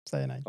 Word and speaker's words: cyanide: Some gases, which kind of the one of the stink bomb kind of poses the same thing, and like hydrogen cyanide. Did I say it right? – Yes, cyanide.